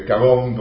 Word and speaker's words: Kagome: Bueno, ahí hay una historiadora de Kenia, se llama Kagome con K, Kagome, porque bueno, fueron no, colonia francesa, claro. La E no se pronuncia, excelente,